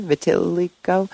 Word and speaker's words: vitiligo: So I'm no ophthalmologist, but I do know that leucodermia is what's known as, I don't know how it's pronounced, vitiligo, maybe? Vitiligo? Anyway, it's the thing that Michael Jackson has. – Vitiligo. – Okay, thank you.